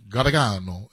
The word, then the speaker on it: Gargano: Gargano, no, Gargano. Supposed to be, like, the old world says it, like, Gargano. Gargano is how you say it.